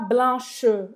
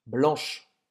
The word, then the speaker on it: blanche: Presta atenção, você, fala, você não fala blanche ou blanche, certo? Esse E no final você não vai pronunciar. Então vai terminar a pronúncia no sh. – Blanche. Blanche. – Blanche. Blanche certo? Termina com esse chiado.